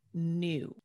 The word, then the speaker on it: knew: It's each of two or more words having the same pronunciation, but different meaning, origins, or spelling, like the word new, K-N-E-W.